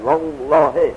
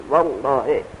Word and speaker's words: والله: ترقیق یعنی خیلی سبک تلفظ میشد بالله، اما اگر فتح و ذمه باشد والله، همچه دهن پر گفته والله، چه فتح باشد چه ذمه علیه الله همچه پر گفته میشد، اونا بهش میگن تفخیم.